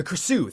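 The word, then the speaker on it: Krasue: And then we have the, I don't want to pronounce this incorrectly. – The Krasu? – The Krasu, thank you.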